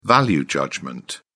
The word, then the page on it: value judgment: British English